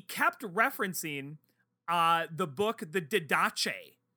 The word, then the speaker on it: Didache: If it's any consolation to you, so you just made reference to the Didache, and you pronounced it correctly, and I've grown to appreciate that.